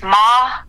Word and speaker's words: Maher: And my last name, I just tell people, it's like if you cut it in half. – Uh-huh. – It's Maher. And her.